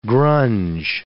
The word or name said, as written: grunge